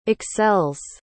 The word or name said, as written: excels